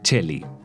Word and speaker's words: coeli: Celis, del italiano coeli, que significa cielo y se pronuncia cheli.